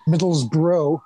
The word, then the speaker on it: Middlesbrough: Gotta say, well done with the pronunciation there. – Thank you. Middlesbrough. – No, just Middlesbrough. Very good. Yep.